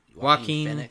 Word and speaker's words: Joaquin: Oh, Joaquin Phoenix.